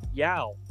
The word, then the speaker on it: Yao: Every time I hear Yao Ming's name, I always think of the commercial that he did with Yogi Berra. I think it's a Aflac commercial. And Yao was trying to get Yogi to say his name correctly. He's like, Yao. Yo? No. Yao.